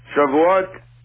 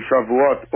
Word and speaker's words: Shavuot: Shavuot is, if you go and look at the Hebrew word for Shavuot or Shavuot, depending on the pronunciation.